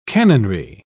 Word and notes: canonry: British English

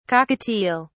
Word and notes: cockatiel(s): British English

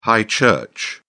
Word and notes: high church: British English

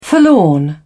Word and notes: forlorn: British English

